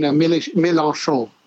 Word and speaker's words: Mélenchon: You mean Mélenchon.